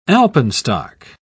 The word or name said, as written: alpenstock